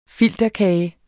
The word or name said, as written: filterkage